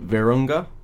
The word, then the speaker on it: Virunga: So my pick is a Netflix documentary that came out in 2014 called Virunga. I think that's how you pronounce it. – Yes.